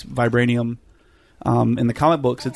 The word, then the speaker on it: vibranium: Vibranium or...